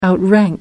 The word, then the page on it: outrank: American English